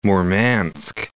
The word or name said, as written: Murmansk